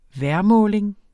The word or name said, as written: vejrmåling